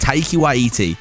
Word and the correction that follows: Taika Waititi: Taika Waititi! I said it wrong then!